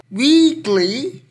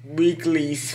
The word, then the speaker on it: Weeekly: So Weeekly or because there's three E's uh, or Weeekly in Korean pronunciation is from Play M.